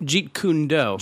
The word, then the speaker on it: Jeet Kune Do: How do you say it? – Jeet Kune Do.